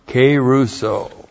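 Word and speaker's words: kerusso: And then keruso. K long E R U S S long O.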